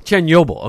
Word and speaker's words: Chernobyl: Chernobyl, okay.